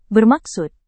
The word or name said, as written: bermaksud